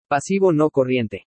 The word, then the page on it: PASIVO NO CORRIENTE: spanish